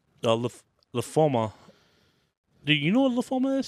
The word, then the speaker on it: lymphoma: Tucker out of lymphobia? – Yes. – Lymphomia? Lymphoma, my bad. I've said it wrong this whole fucking time.